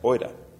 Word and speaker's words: oida: And you might think I've made a typo here, but that's how it should be, but it's pronounced oida.